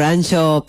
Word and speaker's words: Anxo: escrito por Ancho Pérez Rodríguez. Ancho se escribe A-N-X-O.